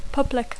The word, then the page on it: public: English